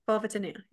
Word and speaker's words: vulvodynia: So I'm probably going to say this wrong, vulvodynia, dynia. – Vulvodynia, yeah.